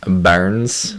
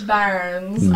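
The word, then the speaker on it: Børns: Technically you're supposed to pronounce it Barnes. – Barnes. I really don't know how to say it. – Barnes. Yeah, I have a Swedish friend, and she was like, this is how you do it.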